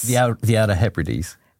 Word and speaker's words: Outer Hebrides: Outer Hebrides? Hebrides? – The Outer Hebrides.